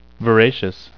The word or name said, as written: voracious